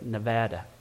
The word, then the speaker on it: Nevada: That's how they say it, Nevada.